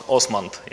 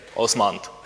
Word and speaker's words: OsmAnd: At first, the name of our application we pronounce Osmand. Yeah. During this conference, I heard other variants too, but we say Osmand.